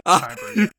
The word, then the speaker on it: Kyber: Well, they did steal all those Kyber crystals. I say that right? – Kyber, yeah.